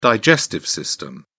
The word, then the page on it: digestive system: British English